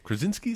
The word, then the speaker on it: Krasinski: Krasinski, is that how you say his name? From The Office? – That's good, yeah.